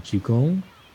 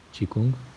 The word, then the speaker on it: Qigong: Se pronuncia como Jinan Qigong pero en México podemos decir Chineng, Chikung. Y em,